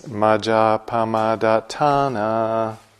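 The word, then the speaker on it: majjapamādaṭṭhānā: The way that Sally and I say it is majapamadatana. Normally in Pali, if there's a long A or a long accent over a vowel, that's the syllable that gets the emphasis. But it's also the case that when there's a double consonant, like the D-A-T-T, that's also an inclination to emphasis.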